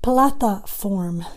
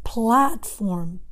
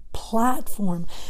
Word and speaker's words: platform: Sometimes the students say plataform, especially students who speak Spanish or Portuguese. Be careful, it's not plataform, it's platform, platform.